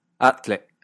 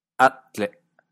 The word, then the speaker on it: atle: Poseía un golpe glotálico, como en atle, nada, atle, atle.